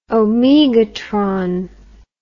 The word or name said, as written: omegatron